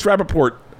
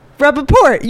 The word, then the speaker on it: Rapaport: You kept saying Rapaport. – It's Rapaport. – Rapaport. You were saying it like with an accent.